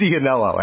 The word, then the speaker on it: Iannello: Is it pronounced Iannello? – I believe it is Iannello, actually. I know, I said it's spelled with an I, but it's some Italian shit.